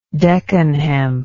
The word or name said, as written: deccan hemp